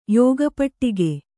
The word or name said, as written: ಯೋಗಪಟ್ಟಿಕೆ